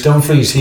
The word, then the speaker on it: Dumfries: Their right wing back, I think I was trying to pronounce his name earlier on, Dumfries. Is that his name? – Denzel Dumfries. – Dumfries.